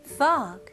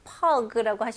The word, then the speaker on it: fog: 이거는 발음을 F 발음으로 하셔야죠. 그래서 pog라고 하시면 안 되고 입술이 붙으면 안 돼요. fog. 그리고 G 발음을 명확히 잘 해주셔야 되고요. 여기서는 이 O 발음, 이거는 입술이 동그래지면 안 되고요. 이게 턱이 내려가는 느낌으로 fog. fog 이렇게 나와야 됩니다. 그리고 모음이 살짝 길면 더 좋고요.